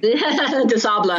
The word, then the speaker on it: des Sables: What about, I mean, and I'm going to pronounce this wrong. I always have, I always read it, but the Marathon des Sables, right? – De Sable. – De Sable. Okay.